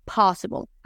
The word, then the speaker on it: possible: Y vamos a decir possible. Aquí la clave es que en inglés las P son muy fuertes y explosivas. No possible, sino possible.